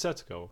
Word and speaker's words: Setsuko: It's like Setsuko. Is still starving to death, and she is going absolutely delirious. – Have you can I see been her saying Setsuko? – It's Setsuko. – Yeah. It is Setsuko.